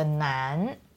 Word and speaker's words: 难: So we have the nan, which is the second tone, Nan guo, you're sad.